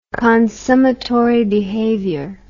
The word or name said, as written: consummatory behavior